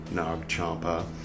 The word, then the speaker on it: Nag Champa: So it's spelled like nag, but pronounced more like eggnog, right? Nag Hammadi, Nag Champa.